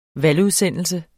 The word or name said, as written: valgudsendelse